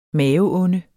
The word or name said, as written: maveonde